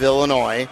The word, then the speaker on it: Illinois: It's like the joke is, I saw one of these old jokes, that we take the S off of Illinois, since we don't pronounce it, and then we put it on the end of most things, like jewels, you know?